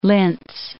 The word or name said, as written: Linz